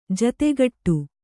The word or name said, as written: ಜತೆಗೂಡು